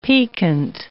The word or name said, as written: piquant